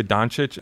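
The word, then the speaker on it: Dončić: Luka Doncic, am I saying that right? I don't watch a lot of basketball. – Yeah, just got to pronounce the chitch at the end and you're good.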